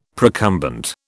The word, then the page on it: procumbent: British English